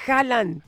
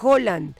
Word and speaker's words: Haaland: Oye, y que por cierto, estaba viendo en un video que de un noruego que dice que no se dice Haaland, se dice Haaland, porque en Noruega la doble A se pronuncia como O, pero todo el mundo le dice Haaland, pero lo correcto es Haaland.